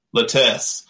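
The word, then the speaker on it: Lutece: How do you say their name? Lutuse or? – Lutes.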